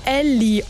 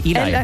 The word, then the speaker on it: Ely: Also E-L-Y, okay. Weißt du, wie ich das am Anfang ausgesprochen habe? – Nee. – Ellie Oaks. – Ah, nee, Eli.